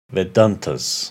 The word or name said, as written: Vedantas